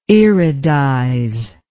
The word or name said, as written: iridize